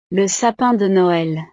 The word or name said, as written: le sapin de noel